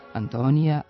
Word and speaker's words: Antonija: Također u većini evropskih jezika ime generalnog sekretara Ujedinjenih nacija u takozvanim kosim padežima nema J, ali u srpskom ga ima, pa se izgovara i piše Antonija, Antoniju, Antonijem.